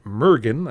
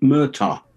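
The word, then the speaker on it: Murtagh: Mergen, I believe is the name. It's pronounced Murta.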